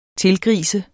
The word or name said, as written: tilgrise